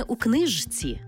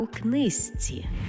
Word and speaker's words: у книжці: І не «у книжці», а «у книзці».